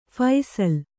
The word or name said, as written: ಫಸಲು